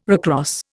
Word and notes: recross: Indian

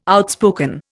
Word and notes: outspoken: Indian

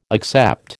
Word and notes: accept: American English

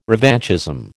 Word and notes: revanchism: American English

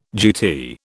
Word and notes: GT: British English

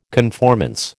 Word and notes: conformance: American English

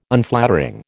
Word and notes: Unflattering: American English

